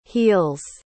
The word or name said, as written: heels